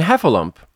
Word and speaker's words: Heffalump: In Heffalump, the hef is the strongest. And the other two syllables? schwas.